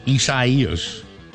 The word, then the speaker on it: Isaias: Now, Isaias. Although a lot of people go, you're pronouncing it wrong. No, I'm not.